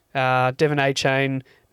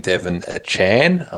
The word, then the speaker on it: Achane: The piece that's really interesting is evaluating Devin Achane, I believe it's pronounced, Devin Achane, who looks unbelievable.